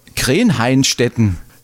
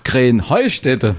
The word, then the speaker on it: Krähenheustätte: Ja, ihr saget immer Krähenhainstetten. Und dabei heißt es doch Krähenheustätte, oder wie? – Krähenheustätte. – Ja, genau, und das ist die Betonung auf dem Heu.